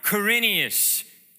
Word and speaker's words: Quirinius: When Caesar Augustus had issued a decree that all the world should be registered, during the time Quirinius was governor of Syria.